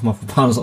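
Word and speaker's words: Mavropanos: Ich habe übrigens das herausgefunden, Janik, dass Mafropanos doch Mafropanos ausgesprochen wird.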